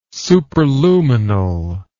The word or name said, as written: superluminal